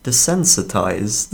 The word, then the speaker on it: desensitized: Did you pronounce that? Desensitized. – Desynthesized. Desynthesized. Wait, what did... – Desensitized. – Desynthesized. Desensitized. – There we go.